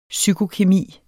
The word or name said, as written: psykokemi